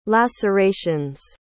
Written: lacerations

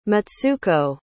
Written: mitsuko

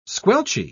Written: squelchy

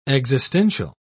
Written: existential

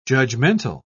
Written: judgmental

